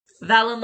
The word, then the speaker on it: Valen: Valen like Valentine.